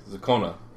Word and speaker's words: Zakona: it was supposed to be Zakona. It would help if I spelled it correctly. – If you reverse the N and the O, it's very hard to pronounce. – Yeah, Zakona, sorry.